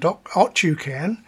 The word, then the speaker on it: Otyukan: The first one, it's the same band, Otyukan, O-T-Y-K-N, but the Y is pronounced like the Russian Y.